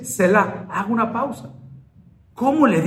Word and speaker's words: Selah: Aquí hay una palabra en los Salmos que no mucho le ponemos atención, que es Selah. – Se escribe S-E-L-A-H.